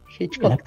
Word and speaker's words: Hitchcock: Por ejemplo, me recuerda mucho el tema de las películas de Alfred Hitchcock, ¿es así que se pronuncia? No sé si... – Hitchcock, las, sí.